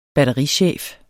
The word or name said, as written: batterichef